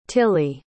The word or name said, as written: tillie